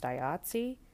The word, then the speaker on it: IATSE: The additional component of the labor situation and strike on set, people who were in the okay, guys, I called it IATSE. It's apparently pronounced IATSE, but like, how was I supposed to know that? It's mostly vowels.